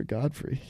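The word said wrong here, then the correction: Gottfried: And it's like it's a D at the end too, because it's like fried. And I guess I've just been saying it wrong like my entire life, and I didn't realize it until, guess it was too late.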